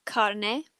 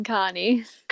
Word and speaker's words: carne: But that's not the pronunciation that normally we hear, isn't it? How people usually pronounce the dish in English? – chili con carne. – Carne. No. So if you're listening to us, guys, don't say carne.